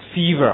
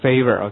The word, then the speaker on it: fever: 很多同学这个单词的读音非常不好，这是一个什么长元音啊，记住长音 e 叫做 fever 而不是 favor，